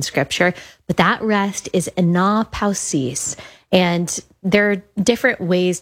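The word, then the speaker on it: anapausis (ἀνάπαυσις): It's a little bit strange how you say it, anapausis. If you read it like in our English dialect, we'd probably say anapausis.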